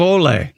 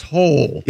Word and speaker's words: Hole: Ole. – Yeah. – Ole. – But it's pronounced Hole. – Yeah. – Hole? – Detective Hole. Yeah. – It's spelled H-O-L-E.